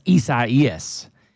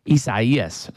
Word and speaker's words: Isaias: And this guy clearly said it's Isaias. Isaias.